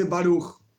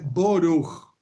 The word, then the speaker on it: Baruch: En hebreo sencillo, se en hebreo moderno se dice Baruch. El hebreo, como pronuncio yo, por ejemplo, es una pronunciación ashkenazí del estilo rusa, se dice boruch, no baruch, boruch. Hay otro tipo de pronunciación, húngara, por ejemplo, burij.